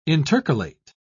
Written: intercalate

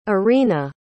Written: orina

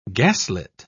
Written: gaslit

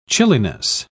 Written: chilliness

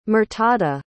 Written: murtada

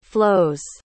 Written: flows

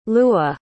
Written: lewa

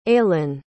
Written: ailen

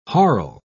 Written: harl

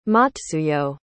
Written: matsuyo

